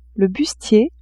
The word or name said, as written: bustier